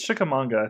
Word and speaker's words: Chickamauga: I think it's Chickamauga. I think you're saying it right.